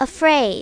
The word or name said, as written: afraid